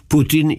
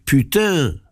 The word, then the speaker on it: Putin: Dziś główni aktorzy dramatu światowego zwą się Putin i Biden. Francuzi przestrzegają wymowy rosyjskiej jego nazwiska. Gdyby stosowali normy francuskie, mówiliby putain, co znaczy kurwa.